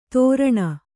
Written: ಧೋರಣ

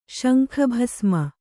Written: ಶಂಖಭಸ್ಮ

